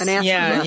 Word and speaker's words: anathema: So it's, witches were considered, how do you say that word? Anathema? – Yes. – Anathema to the church.